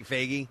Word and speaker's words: Feige: And as a new Avengers Endgame trailer dropped, it is produced by Kevin Feige, of course, with, I never remember how to pronounce it. – Feige.